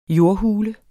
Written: jordhule

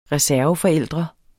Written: reserveforældre

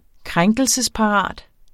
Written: krænkelsesparat

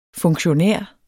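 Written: FUNKTIONÆREN

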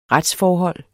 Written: retsforhold

